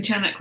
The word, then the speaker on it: Chanak: So if you're pronouncing Chanak wrong, I am too. That's how I read it, how I say it.